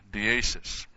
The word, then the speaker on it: deesis: But the word for prayer here, deesis, D and then the long A.